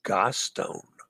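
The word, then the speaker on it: Gaston: Watch Disney's BATB for the common mispronunciation. It's France. It's pronounced Gostone. Gostone.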